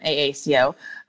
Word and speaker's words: AACO: So at AACO, that's how we pronounce our acronym, AACO, we work with all of the county elected positions.